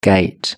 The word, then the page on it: gate: British English